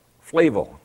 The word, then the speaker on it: Flavel: And John Flavel, when he caught, is it Flavel or Flavel? Flavel, thank you.